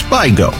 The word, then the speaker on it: Vigo: His name was Francis Vigo, locally pronounced Vigo.